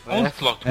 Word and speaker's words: Onslaught: Onslaught.